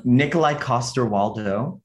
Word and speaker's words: Nikolai Coster-Waldau: Nikolai Coster-Waldau.